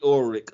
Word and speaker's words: Ulrich: Skeet Ulrich, who kind of looks like these two guys. – It's pronounced like a K, not a CH. – It's a K.